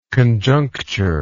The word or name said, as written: conjunctural